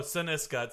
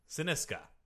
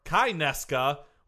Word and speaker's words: Cynisca: Oh, Paniska. – No, Siniska. It sounds like Paniska. – Oh, that's true. – Yep. – Scandinavian. – No. – Then I'm at a loss. – It's spelled C-Y-N-I-S-C-A. Syniska. – I-S-C-A.